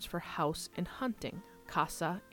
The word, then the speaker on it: casa: But if I said them using the distinción pronunciation, you can tell the difference between the words very easily. So the word for house is casa, spelled C-A-S-A, and the word for hunting is caza, spelled C-A-Z-A.